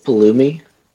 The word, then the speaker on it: Pulumi: Have you guys heard of Pulumi? – God bless you.